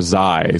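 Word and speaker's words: X Æ A-12: It's she said A like Kai, and he says it's like cash. – I feel like they're already disagreeing on a lot for people that just had a baby. – Or Zai, sorry, because the X is pronounced Z.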